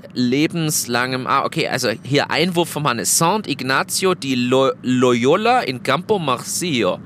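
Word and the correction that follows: Sant'Ignazio: Achso, ist ja aber eigentlich italienisch. Jetzt habe ich es spanisch ausgesprochen. Also San Ignazio äh, ist die Kirche.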